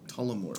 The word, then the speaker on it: Tullamore: As I'm drinking more Telemord. – Yeah. Telemordu. You said it wrong. – Did I? – Yeah. Tullamore.